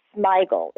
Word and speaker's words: Smigel: But my biggest surprise was Robert Smigel. Is that how you say it? – Yep.